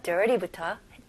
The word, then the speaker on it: dirty: 그래서 여기 중간에 있는 R 발음을 확실하게 해주셔야 됩니다. 오케이, 그래서 dirty가 아니고 dirty. T. 그리고 여기서 T도 너무 강하게 안 하고 좀 더 가벼운 D 발음으로 한번 해볼게요.